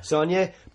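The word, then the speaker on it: Saulnier: How do you pronounce his last name? Jeremy? – Sonier? – Yeah. – Sonier.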